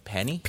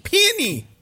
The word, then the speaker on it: peony: How do you say it? Penny? – Peony. – Peony. – Peony? – Peony. – Oh, flower. Yeah. Peony.